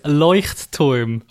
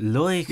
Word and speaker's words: Leuchtturm: Yes, especially with the Lechtrum. How do you say that? – I can't remember. I think I said Lechtrum or something. – It's Leuchtturm. – Oh, that sounds a lot better, doesn't it? Leuchtturm.